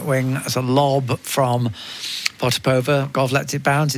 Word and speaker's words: Potapova: She is happy with the pronunciation Potapova. I think in Russia they would say it slightly differently, but they'd also...